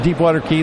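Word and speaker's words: Deepwater Cay: But, yeah, you know, you get Deepwater Key, they definitely got a very special setup over there, and it's Deepwater K, or C-A-Y is how it's spelled, .com.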